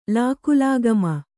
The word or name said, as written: ಲಾಕುಲಾಗಮ